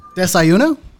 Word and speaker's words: desayuno: Desayuno? Is that right? Yeah. Fuck it. Nobody knows. It's impossible to say. – That's close enough. – I believe it's pronounced desayuno.